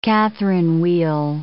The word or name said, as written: Catherine wheel